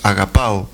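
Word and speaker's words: agapao (ἀγαπάω): Ágape es muy conocido, pero el término bien pronunciado es agapao.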